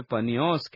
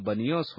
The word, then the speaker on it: बनियोस: यह वेदी पान देवता की थी जिसे वे पनियोस कहते थे। अरबी लोग प का उच्चारण ब करते हैं, अतः उसका नाम बनियोस हो गया।